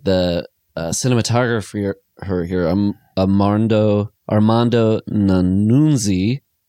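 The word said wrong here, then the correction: Nannuzzi: Nanuzzi? Um, I think you mean Armando Nanuzzi. – Thank you. I was so close to the pronunciation, but uh, like I love those like um, oh, and Dino De Laurentiis did uh, produce Manhunter and stuff too.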